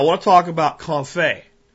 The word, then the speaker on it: confit: It's confit. And if you want to look up information on it, it's spelled French, so it doesn't sound like it's spelled in English phonetically. It's C-O-N-F-I-T, like confit is how you would phonetically pronounce it, but confit.